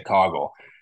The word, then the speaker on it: Coggle: We talked, you know, Jacob Cog, Coggle, which I think is how they pronounce it. We've been saying Coffle for a while. They they say Coggle he was out of the game, and Chase Evans slid over to center, and we saw Aiden Logan come in at guard.